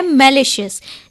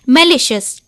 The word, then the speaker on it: malicious: और इसी के साथ हमारा जो आज का इंग्लिश वर्ड है वो है मैलिशियस, जिसकी स्पेलिंग है MALICIOUS, मैलिशियस, जिसका अर्थ होता है दुर्भावनापूर्ण, द्वेषपूर्ण या फिर हानि पहुंचाने वाली या फिर किसी बुरे भावना से किया गया कोई काम।